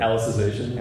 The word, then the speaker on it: Alicization: Alicization.